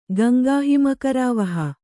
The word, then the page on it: ಗಂಗಾಹಿಮಕರಾವಹ: Gaŋgāhimakarāvaha